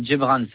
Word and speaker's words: Djebrane: C'est Gibran, ça s'écrit DJEBRANE.